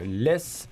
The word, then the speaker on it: LE2S: C'était le titre euh, Harmful du groupe euh, Less. Euh, non pas comme la laisse du chien, mais qui s'écrit slash slash LE2S.